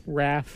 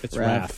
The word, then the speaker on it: Raph: Or is it Rafe Koster? How do you pronounce that? – Raph. – It's Raph.